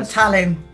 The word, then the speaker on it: Tallinn: And I know you are from, how do you pronounce it? Is it Tallinn in Estonia? – Yeah, that's right. I'm from Tallinn.